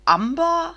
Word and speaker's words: Amber: Und Amber hätte ich persönlich jetzt Englisch ausgesprochen, also Amber,